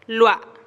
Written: lua

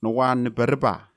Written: nowaneparepa

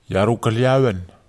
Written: iaru kaliawen

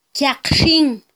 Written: kyaqxin

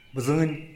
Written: bzïn

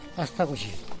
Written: astagudxi

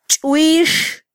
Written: chwix

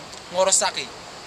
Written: ngorosaki

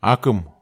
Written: akem

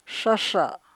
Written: shasha

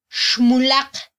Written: xmulaq

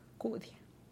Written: kudia

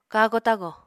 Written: kago-tago

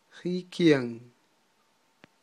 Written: jikyan

